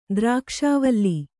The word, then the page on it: ದ್ರಾಕ್ಷಾವಲ್ಲಿ: kannada